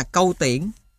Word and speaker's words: Câu Tiễn: Lưỡi kiếm có khắc sọc hình quả trám, khắc 8 chữ lệ kiểu chân chim: Việt Vương Cưu Thiển tự tác dụng kiếm. Ghi chú: Cưu Thiển là chữ cổ, nay đọc là Câu Tiễn, hết ghi chú.